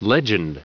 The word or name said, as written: legend